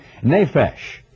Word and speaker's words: nephesh: In the Old Testament, the Bible uses the word soul in the English language, but that comes from a Hebrew word that is pronounced in the English, if not spelled that way, nephesh.